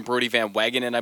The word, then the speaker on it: Van Wagenen: They've had some issues with obviously a guy like Zach Scott having the DUI, and you know before that, I believe you say his name, Brody Van Wagenen, I believe is the correct pronunciation of that.